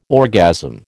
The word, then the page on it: orgasm: American English